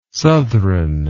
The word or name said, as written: southron